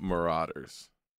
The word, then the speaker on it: Marauders: Did I say the name wrong? – I'm not going to say it. – No, fucking say it. – It's Marauders.